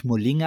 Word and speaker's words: Moling: Ich glaube, als Name spricht man eigentlich Moling aus. Auch, dass es ein asiatischer klingt. Auch schreibt man es Moling, also M-O-L-I-N-G.